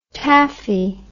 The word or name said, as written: tafe